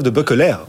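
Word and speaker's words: De Beukelaer: Christophe de Boeckeler, on prononce son nom comme il faut, belge, ouais.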